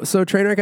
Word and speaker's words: Treignwreck: So Trainwreck Entertainment, if you want to find out more, it is at Trainwreck Official. It's spelled TREIGN wreck. Because it's fancy.